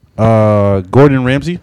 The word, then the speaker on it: Gordon Ramsay: Gordon Ramsay, am I saying that right? – Gordon, yeah.